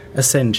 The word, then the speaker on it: Assange: Aber der WikiLeaks-Founder heißt übrigens Julian Assange, oder wie man das ausspricht, Assange. Assange, ja, spricht man es aus.